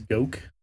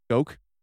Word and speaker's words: Goku: I can confirm we've been pronouncing his name correctly. It is Goku. Goku. At least that's what Google says.